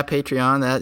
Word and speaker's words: Patreon: There's services like, am I saying this right, Patreon? – Yeah, Patreon.